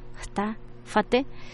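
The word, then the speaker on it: Jta Fatee: Eh, en este caso se pronuncia tal cual, está fate.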